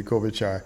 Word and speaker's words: Klobuchar: And I want to thank you, WhatsApper, for I want to thank you for giving me the correct pronunciation to Amy Klobuchar, because she seems to be one that is shining brightly at this stage.